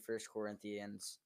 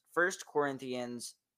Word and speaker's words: First Corinthians: I feel like you guys are all just going to judge me about not being able to say First Corinthians. I said it right. I feel like a good person. It's First Corinthians 10:13.